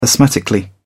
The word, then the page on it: asthmatically: British English